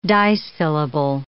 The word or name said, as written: disyllable